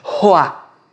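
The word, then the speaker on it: roá (רוח): Esse soprar é roar ou ruar um termo mais tradicional, mas a pronúncia roá.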